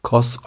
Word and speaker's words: Kos: Ich war aber mal auf Kos oder ich glaube, heute spricht man es neugriechisch Kos aus.